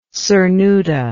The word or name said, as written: cernuda